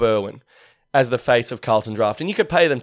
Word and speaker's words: Carlton Draught: When I take a sip of the Carlton Draft, pronounced correctly, thank you very much,